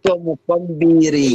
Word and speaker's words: Zikomo Kwambiri: My pastor, Zikomo Zimbiri Kwambiri. Is that it correct? – Zikomo Kwambiri. – Yes!